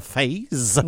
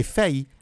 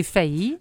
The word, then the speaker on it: Fays: Il s'appelle, ça s'écrit F-A-Y-S, donc on serait porté d'appeler ça phase. – Oui. – Mais non, c'est un peu comme pays, c'est failli. – OK. – C'est, on le prononce comme si c'était le mot pays.